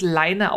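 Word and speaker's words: Laine: Ich habe mir das, wir wissen jetzt, dass es Leine ausgesprochen wird. Das ist ein finnisches Wort ähm, mhm.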